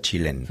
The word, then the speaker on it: Tilene: Bueno, por corregir un poco, centrar, Tilene se escribe, pero se pronuncia Chilén.